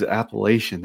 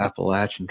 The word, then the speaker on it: Appalachian: I was like, that's not how you say it, but oh, it was Appalachian. That's what he said. He said the Appalachian Trail, and I had to bite my tongue. It's the Appalachian Trail, buddy.